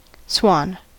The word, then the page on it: swan: American English